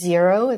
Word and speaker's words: Xero: I use Xero. It's spelled X-E-R-O.